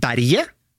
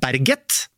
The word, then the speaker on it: Berget: For der har det jo nå vært litt diskusjon I Sverige, hvordan skal etternavnet uttales? Så det, da har jo noen svenske kommentatorer sagt Jo Inge Berge, selvfølgelig. Og så mener noen at det Berget, med tydelig T, og så vil noen si at det Jo Inge Berge.